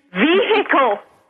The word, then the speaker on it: vehicle: And vehicle. – Vehicle, I know. – It's a silent H, people.